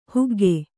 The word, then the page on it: ಹೊಗ್ಗು: hoggu